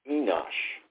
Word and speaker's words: Enosh: That's E-N-O-S-H, Enosh. Now, Seth's son was called Enosh, and actually this is pronounced Enosh, and that word actually means mortal.